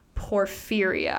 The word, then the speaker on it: porphyria: So the main disease that people think really sparked these vampire superstitions was porphyria. I think I'm saying that right. – Yeah, sounds right.